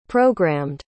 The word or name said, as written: programmed